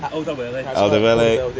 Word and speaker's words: Alderweireld: Alderweireld.